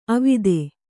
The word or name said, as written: ಅವಿಧಾ